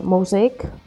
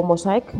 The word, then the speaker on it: Mosaic: Pues nada, el siguiente es Mosaic, o Mosaic, escrito tal cual se escribe.